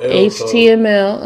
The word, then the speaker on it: HTML: People got, started getting fancy and shit, and they start learning them uh, HTML to HMTL. HTML. Uh huh. Yeah, I, I said it right, I think.